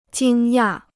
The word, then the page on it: 惊讶: jīng yà